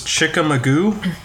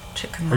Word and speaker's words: Chickamauga: This one, the second one was Chickamauga. Chickamauga? – Like Mr. Magoo? – Well, Chickamauga. – Chickamauga. Oh, I didn't see. – I like Chickamauga. – Wait, where's the N? – Chickamauga.